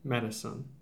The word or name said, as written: Matteson